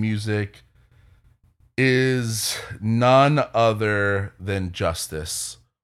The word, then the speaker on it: Justice: you pronounce, you call it Justice. That's how the French call them.